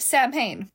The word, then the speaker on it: Samhain: The word is spelled S-A-M-H-A-I-N. And you would think that it's pronounced Samhain. It is not. It's pronounced Samhain because Celtic is a language that says fuck you to consonants.